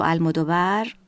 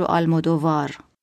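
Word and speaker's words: Almodóvar: کارگردان اسپانیایی پدرو المودوور رو میگیم پدرو آلمودووار که خب درست نیست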